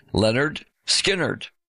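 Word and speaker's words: Lynyrd Skynyrd: The title of the album was a pronunciation guide because nobody could figure out how to say the band's name, pronounced Leonard Skinnerd.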